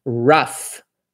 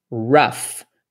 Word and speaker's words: rough: Agora vamos, por exemplo, palavra, uma coisa que é pronuncia com som de F, né? Então, por exemplo, rough, rough.